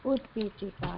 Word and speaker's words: utpitika: Utpitika, yes. – Yeah. – Okay. Thank you. – Short U. Sorry. Long E, short T, utpitika.